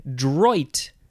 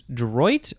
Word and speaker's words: droit: Droit is next. Yeah, I think it's droit or also droit. You could say that. Droit. I don't have a good French pronunciation. It is spelled D-R-O-I-T, noun from the 15th century. It's a legal right, R-I-G-H-T.